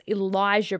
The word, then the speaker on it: Elijah: Now, I actually think it's Elijah Brittman. It's spelt like Alicia, but it's Elijah.